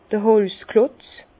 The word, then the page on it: d'r Holzklotz: Bas-Rhin; Herrlisheim